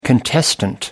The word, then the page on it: contestant: American English